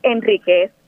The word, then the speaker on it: Enriquez: Es Enriquez porque no se acentúa.